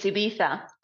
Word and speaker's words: Ibiza: It's Ibiza.